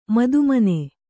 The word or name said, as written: ಮದುಮನೆ